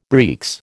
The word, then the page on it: breeks: American English